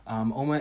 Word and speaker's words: Uoma: Oma is spelled U-O-M-A, okay?